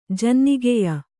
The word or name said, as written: ಜನ್ನಿಗೆಯ